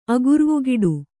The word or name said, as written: ಅಗುರ್ವುಗಿಡು